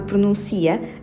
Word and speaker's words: pronuncia: Por outras palavras, no caso de pronuncia, a sílaba mais forte é o si, pronuncia.